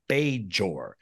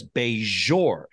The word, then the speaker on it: Bajor: Now here's the big question, because in this scene, she pronounces B-A-J-O-R as Bajor. It's a hard J. – Yeah. – Now, when I asked Armin on a prior episode, how do you pronounce it? He says Bajor. He does a zhuzh to it. And also you, Terry, in this episode, Bajor. – Say Bajor. You zhuzh it as well.